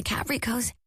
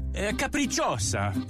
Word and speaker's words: Capricciosa: Try them on the Capricos. Wait, how do you say this? – Capricciosa.